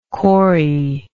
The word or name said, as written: cory